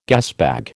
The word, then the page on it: gasbag: American English